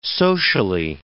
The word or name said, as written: socially